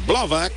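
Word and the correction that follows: Blubaugh: Y su apellido se pronuncia Bluba. Bluba. Se escribe Blubach con GH al final.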